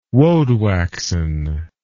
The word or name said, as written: woadwaxen